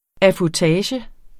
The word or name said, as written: affutage